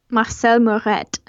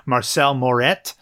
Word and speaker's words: Marcel Moret: How do you say this name in French here? – Marcel Moret. – There you go. Okay. I actually looked it up and now I forgot. So Marcel Moret.